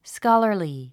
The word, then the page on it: scholarly: skάlə r li; スカラァリィ